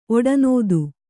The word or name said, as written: ಒಡನೋದಿ